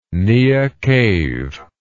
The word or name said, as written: niah cave